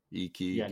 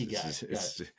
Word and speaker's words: Ikigai: I-K-I. G-A-I. – Okay. – Ikigai. Yeah. – Ikigai. Yeah, nothing like it's spelled, right? – No, it's not. It's Ikigai. – Yeah, Ikigai. Nice.